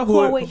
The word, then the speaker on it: Huawei: Comment tu prononces ça? Huawei. – Huawei.